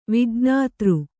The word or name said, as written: ವಿಜ್ಞಾತೃ